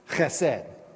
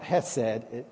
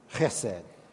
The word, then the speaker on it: chesed: another word that shows up three times in the book of Ruth is, I practice with Mark saying this, chesed. You got to put spit in the back of your mouth to say it right. Chesed. It's not hesed, it's chesed.